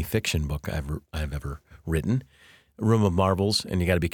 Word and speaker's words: Room of Marvels: It's not Room of Marbles. Some folks thought that was the title when I just say it. Room of Marvels with a V is a book that I wrote but didn't mean to write.